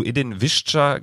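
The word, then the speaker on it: Edin Višća: Äh, zu Edin Visca ganz kurz. So wird er nämlich ausgesprochen.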